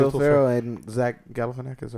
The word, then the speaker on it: Galifianakis: And Will Ferrell's going to punch Galifianakis' character, and he moves out of the way, and I'm proud of myself for saying that correctly.